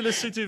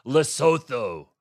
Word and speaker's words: Lesotho: Would you like me to say Lesotho as well? – No, you do Lesotho very well. It's President Trump who goes Lesotho.